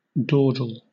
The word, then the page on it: dawdle: British English